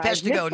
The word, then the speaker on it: Peshtigo: And so, you know, the ones that folks hear about in terms of the water being contaminated in French Island and Peshtigo. And forgive me, I'm from Michigan. – So it's Peshtigo. No worries. You were pretty close.